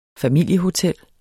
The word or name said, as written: familiehotel